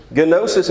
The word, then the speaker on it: gnosis: It's gnosis, and yes, you do pronounce the initial G in Greek. There's two syllables there.